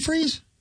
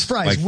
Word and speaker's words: Fries: But did we used to call him Freeze? – I think when we first signed him, we're like, how do you say it? But it is fries. – It's fries. – Like French fries.